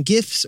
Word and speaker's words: GIF: I'm always going GIF. – All right, I'll go with GIF too. Let's just go with GIF. – Hard no on the soft G.